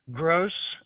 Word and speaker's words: Grosz: Gross. It's G-R-O-S-Z. Last letter Z like zebra. – G-R-O-S-Z.